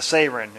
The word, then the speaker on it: seren: A Sarin. It's spelled S-E-R-E-N, but it's pronounced Sarin.